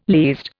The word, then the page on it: least: Indian